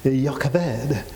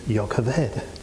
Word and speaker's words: Jochebed: Or if I try to pronounce it a little bit more Hebrew, like Jochebed, because the B, Jochebed, is it looks as it's spelled, is actually pronounced like a V, so it's Jochebed.